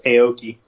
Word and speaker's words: Aoki: You just butchered that enunciation. It's Aoki.